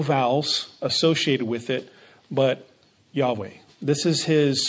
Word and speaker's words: Yahweh: And then later on in the passage, we see the actual name of God, which the best that we can pronounce it is Yahweh. That's probably the closest approximation, Yahweh.